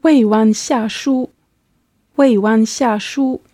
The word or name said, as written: weiwanxiashu